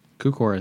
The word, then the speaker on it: Cukor: Is that, am I saying that right? – Cukor? So. – Cukor? – Cukor, I think is right. – I am Cukor. – Yeah. – That sounds pretty good.